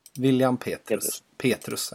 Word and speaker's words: William Petrus: Man skulle kunna tro att han heter William Petrius. Men William Petrus, eller hur uttalas det? – William Petrus, ja, exakt.